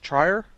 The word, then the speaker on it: Trier: It's, I think it's the third film in a trilogy that Lars von Trier, I'm not sure how to say his last name. Is it Trier? – Yes.